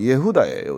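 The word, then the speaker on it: 예후다예요 (יהודה): 유다라고 되어 있는데 이게 히브리식 발음은 예후다예요.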